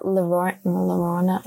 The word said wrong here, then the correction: La Llorona: Whatever you say. – Now you're making me tongue tied. La Llorona.